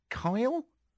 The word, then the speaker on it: Kjell: I think his name, forgive me because I might get the pronunciation wrong in this, it's K-J-E-L-L. Kyle? Is that all right? K-J-E-L-L. What do you think? – That's what it says on the page here. – Kyle. Kyle